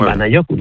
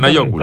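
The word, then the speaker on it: Vatnajökull: Vanayökull, algo así puede ser, ¿no? – Eso, pues mira, oye, lo has dicho bien, Vanayökull. – Bueno, Vanayökull.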